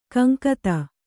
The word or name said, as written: ಗುಂಗುದಿ